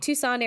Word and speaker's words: Tucson: So Wyatt Earp, a retired peace officer with a notable reputation, reunites with his brothers Virgil and Morgan in, is it Tucson? Is that how you pronounce it? – Yeah, Tucson. – Okay, mm-hmm. Tucson, Arizona, where they venture on toward Tombstone to settle down.